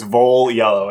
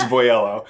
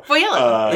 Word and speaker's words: Voiello: Yeah, there are two, both the scenes with Voiello, who, by the way, last episode, I like confidently mispronounced his name. I was like, it's Voiello, and it's not. It's Voiello. – Voiello.